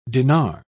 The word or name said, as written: dinar